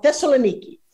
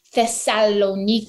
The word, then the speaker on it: Thessaloniki: Thessaloniki. Okay, yes. Come and say it. – Thessaloniki.